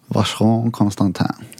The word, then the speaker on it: Vacheron Constantin: Vacheron Constantin. – Vacheron Constantin.